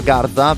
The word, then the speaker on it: Garda: Io non so neanche come si dica Garda, per dire, quindi insomma, giusto, l'ho anche detto giusto, è incredibile, grandi sorprese della vita.